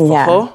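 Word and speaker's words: forró: It's spelled F-O-R-R-O, but it's pronounced fajol.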